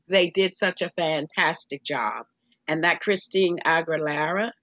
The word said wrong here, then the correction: Christina Aguilera: Christina Aguilera.